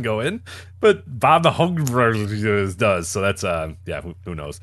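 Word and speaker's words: Houbregs: And then Bob, I never knew how to pronounce his name. Was it Hobregs? Hobregs? – I believe so, yes.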